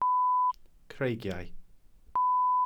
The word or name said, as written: creigiau